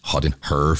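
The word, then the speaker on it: Hot in Herre: Derfor så er Hot in Her med to R, og skal udtales Hot in Her, for at det sidder fuldstændig i skabet.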